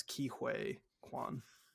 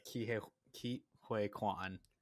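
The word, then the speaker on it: Ke Huy Quan: It's Ki Huy Quan. – Yeah, Ki Huy Quan.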